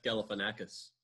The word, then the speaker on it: Galifianakis: Gavalofagakis. – Yeah, Zach Galifianakis. – Really butchered that, though, didn't I? Really butchered it.